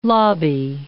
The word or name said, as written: lobby